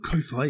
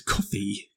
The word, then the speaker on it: Ko-fi: It's not Ko-fi, it's coffee. Come on, Chris.